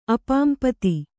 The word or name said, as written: ಅಪಾಂಪತಿ